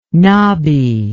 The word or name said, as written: nabi